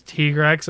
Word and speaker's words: Tigrex: Especially when most they said the official pronunciation was Tigrex, and most people were like, "Nope, Tiger, Tigrex. This is you're not gonna change that for me."